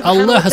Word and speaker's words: Аллах: Слова, состоящие фактически только из букв имени Аллаха Субхана ва Тааля. Взгляните на эти буквы. Алиф, лям, лям, ха. Аллах. Аллах. Она начинается с хамзы, которая произносится на уровне глубокой артикуляции в горле.